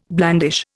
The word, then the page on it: blandish: Indian